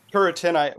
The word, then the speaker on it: Turretin: Turretin. Well, that's how I learned it, Francis Turretin. – Yeah, it's in Turretin.